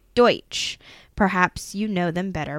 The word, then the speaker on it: Deutsch: Look at you pronouncing that word correctly. – I know Mennonites. – I'm talking about Deutsch. – Deutsch, oh. To give you a better understanding, the Amish are a bifurcation of this group.